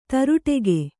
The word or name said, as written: ತ್ರಾಟಕ